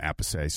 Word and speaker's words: Appice: This was beforehand, and he's with the drummer, Vinny Appice, I call him. Some people say, pronounce his name, Appice, so you'll hear him on here too.